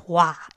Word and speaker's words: Roy: In 1960, with the help of Raoul Waugh, I know that Roy is pronounced Roi in French because I know a lady with Roi as a last name. So in 1960, with the help of Raoul Roi, he created L'Action Socialiste pour l'Indépendance du Québec, which was like the Independence Party of Quebec.